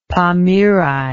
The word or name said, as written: pamiri